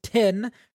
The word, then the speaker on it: Tyn: It is titled Tin, it's spelled T-Y-N, not tin like the metal, but that'd be a crazy connection. T-Y-N, it has Shallan symbol on it.